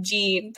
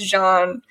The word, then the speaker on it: Jean: Everyone calls him Gene, but his name is Jean.